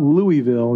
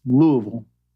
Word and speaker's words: Louisville: By the way, it's not Louisville, it's Louisville. Okay, you got to say it right.